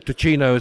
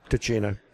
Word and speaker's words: Ticino: Tessin, which is the French way of pronouncing it. Ticino is the Italian way of pronouncing it, and Ticino is Italian-speaking.